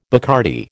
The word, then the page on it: Bacardi: American English